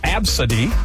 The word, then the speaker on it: Abcde: You know, like A, B, C, D, E, pronounced Absidy.